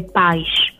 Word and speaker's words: Paz: Paz deriva dal portoghese, infatti la pronuncia, diciamo, esatta è Paz. Uh-huh. Qui ci tengo a precisarlo, perché chi parla portoghese nel gruppo, sennò poi mi bacchettano.